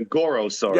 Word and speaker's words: Gorosaurus: Gorgosaurus. – No. I think you're thinking of Gorosaurus.